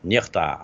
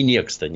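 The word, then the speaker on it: NEXTA: Нехта, она это как никто, условно. Скажем, вот, в переводе. Вот, нехта, она правильно произносится. Не некста, нет.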